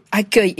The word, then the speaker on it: accueil: Donc, si la fin du mot accueil était écrite E-U-I-L, comme dans fauteuil, deuil ou écureuil, eh bien, on devrait prononcer AXEUIL. Voilà pourquoi a été imaginée cette solution original, certes, inverser les lettres E et U.